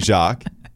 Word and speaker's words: Jacques: Congratulations to Marcel Louis Jacques. – Thank you. – The S is silent on the end of Jacques, by the way, in case you didn't know, who is going to be the ESPN NFL Nation reporter for the Buffalo Bills.